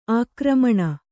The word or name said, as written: ಆಕ್ರಮಣ